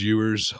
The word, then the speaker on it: Ewers: Yeah, I don't know how to pronounce his last name. – Yeah, Ewers. All I know is it's the last four letters of skewers, and it makes me hungry.